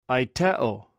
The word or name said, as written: aitew